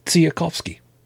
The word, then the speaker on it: Tsiolkovsky: If it sounds like I'm speaking with a little bit of a lisp, I do have a little bit, but no, I pronounced it correctly, Tsiolkovsky.